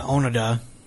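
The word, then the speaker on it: Onoda: So, the otaku, Sakamichi Onada, uh, Onada, that's how you say his name,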